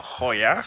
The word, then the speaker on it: Heuer: Hayward, no, Hoya.